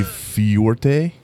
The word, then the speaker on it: fuerte: So I had the Fuerte. – Fuerte? – Fuerte. – Fuerte. – Fuerte. – It's like muerte, but fuerte.